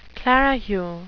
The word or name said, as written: clerihew